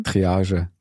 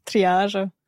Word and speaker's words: Triage: Triage. – Triage.